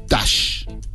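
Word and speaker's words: Tush: en una entrevista Dusty cuenta que es una palabra pronunciada para que suene como rush, que es apurarte, correr, dale, apúrate. Y hay gente que la confunde con la palabra touch, que es tocar en inglés, pero la pronunciación real es Tash, que es una palabra rara que la armaron.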